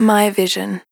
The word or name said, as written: My Vision